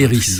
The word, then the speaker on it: Eris: I-R-I-S. – E-R-I-S. E-R-I-S. Et Eris, voilà. Eris. C'est pas Eric, c'est Eris.